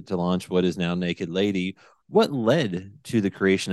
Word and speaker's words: NKD LDY: And for those that don't know, it's it's spelled or stylized N K D L D Y. – Yes, yes. So naked lady without the vowels.